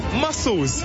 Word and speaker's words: muscles: I would say musless or like massless. Musless? Massless? – What is this? – Like this. – Oh, muscles. – Yes, muscles. Musless.